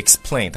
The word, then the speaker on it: explain: 首先第一个呢，就是解释这个单词，有好多同学读的叫 explain，就你看的音标读的是 explain，它这个地方呢，实际上它这个就是 p 的这个读音呢，要把它浊化成为这个 b，是读起来叫 explain，